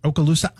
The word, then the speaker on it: Okaloosa: Okaloosa Island.